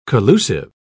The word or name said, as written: collusive